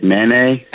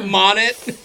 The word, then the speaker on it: Monet: That's how you pronounce it? I thought it was like Manet. – Monet.